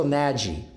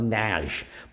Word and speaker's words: Nagy: N-A-G-Y. Rachel Nagy. But it's Hungarian, so it's pronounced Nagy. Most people go Rachel Nagy because it's N-A-G-Y. But Hungarian, that's pronounced Nagy.